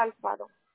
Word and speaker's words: Alfaro: Alondra Alfaro. – Okay. How do you spell that? – A-L-F-A-R-O.